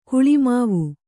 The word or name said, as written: ಗುಲಿಮಾವು